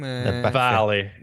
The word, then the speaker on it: Valley: C'est correct la prononciation en anglais? – Valley. – C'était Valley, OK, bon.